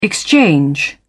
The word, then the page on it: exchange: British English